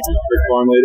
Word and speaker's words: Bric Parmley: Bric Parmley it is.